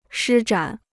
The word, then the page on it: 施展: shī zhǎn